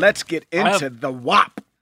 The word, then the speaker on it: WAP: WAP? – Yeah, no, you got it right.